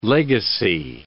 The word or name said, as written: legacy